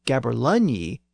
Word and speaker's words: gaberlunzie: Also, a somewhat obscure term for a beggar is a gaberlunzie, spelled G-A-B-E-R-L-U-N-Z-I-E. But again, that Z was substituted for the older letter yog, and the word is still pronounced as gaberlunyi in some older Scots dialects.